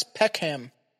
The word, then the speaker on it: Peckham: Is that actually how you pronounce that? – Yes, Peckham. – Is it Peckham? – No, it's Peck and Ham. – So together. Peckham. – Peck, I guess Peckham is how it's the British Peckham.